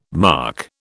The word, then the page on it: marc: British English